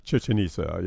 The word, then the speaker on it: Chichen Itza: Chichen Itza. Did I say that right? Chichen Itza? – Chichen Itza, yeah.